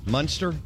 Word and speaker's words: Munster: They have uh, I can't pronounce this. Munster. Yeah, that's correct.